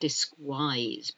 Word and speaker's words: disguise: Disguise is spelt D-I-S-G-U-I-S-E. Disguise. All these words look as if they should be pronounced differently, like disguise, but it's not. It's disguise. Guilty. I'm guilty of this. Are you guilty of it? G-U-I. L-T-Y.